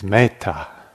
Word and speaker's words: metta: Actually, we call it metta, but that's a mispronunciation. It's metta.